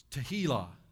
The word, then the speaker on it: tehillah: It's tehillah. It's pronounced like tequila, but it's not.